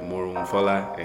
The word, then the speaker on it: Morohunfola: But then I also, depending on who I'm talking to, I might go, okay, Morohunfola, and give them Morohunfola. – The whole, you know, Nigerian. – Yes. Put the emphasis on the right.